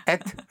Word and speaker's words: êtes: So these are the three exceptions for vous, where you have, where you don't have an E-Z, E-Z, but a one-syllable T-E-S, where you sound the T, one syllable for the T. I call them the three QTs. They're very cute, really. Et, fet, and dit.